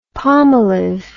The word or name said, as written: palmolive